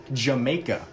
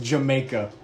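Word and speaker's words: D'yer Mak'er: It looks like Dyer Maker, but you say it with an accent, so it's actually called Jamaica. – What? Yeah, sure. Okay. – Jamaica. – If you spell it out, it looks like Dyer Maker. It's pronounced Jamaica.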